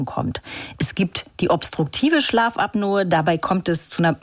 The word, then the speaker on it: Schlafapnoe: Hier ganz kurz der Hinweis, das ist tatsächlich die korrekte Aussprache, Schlafapnoe. Viele Menschen, übrigens auch Ärzte, sprechen aber auch von Schlafapnoe.